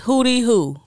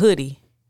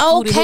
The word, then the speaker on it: Hoody Hoo: H-O-O-D-Y. – It is Hoody Hoo, bitch. – Yeah, that's what it says. – You talking about hoodie. It's not hoodie. It's Hoody Hoo.